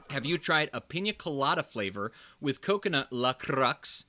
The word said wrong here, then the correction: LaCroix: How do you say that? Your drink that you're drinking? LaCroix. Why did I say La Crux? I don't know. LaCroix.